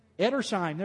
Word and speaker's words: Edersheim: So, this is a book by Alfred, and I never can pronounce his name. Somebody want to try it? I think it's Erdsham. Edersheim, there we go. I'll go with that.